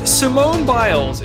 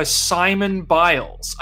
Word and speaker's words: Simone Biles: Simone Biles is one of the most famous athletes on the entire planet, and you just called her Simon Biles.